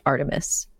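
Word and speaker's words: Art3mis: The blog of a female Gunter named Artthremis, parentheses, pronounced Artemis.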